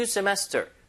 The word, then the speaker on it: semester: 앞에다 new 붙여서 new semester. New semester. 이거 중간의 강세입니다.